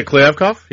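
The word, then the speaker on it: Kliavkoff: Is it Kliavkov? Is that how you say it? – Yeah, Kliavkov. Yeah.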